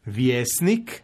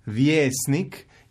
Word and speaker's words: vjesnik: Pa, na primjer, u riječima riječnik, vjesnik, ja ću izgovoriti kratko jer je to pravilno, međutim većina ljudi će izgovoriti riječnik, vjesnik, izgovorit će dugo i onda će pomisliti izgovara se dugo, dakle piše se ije, što nije pravilno.